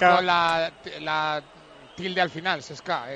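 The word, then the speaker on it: CSKA: El Cheska, bueno, en realidad los rusos son el, dicen que hay que pronunciarlo como TS, Cheska, pero es muy difícil.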